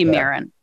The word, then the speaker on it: Mirren: Yeah, it looks like it should be Mirren. – Yeah. – The proper pronunciation is Mirren,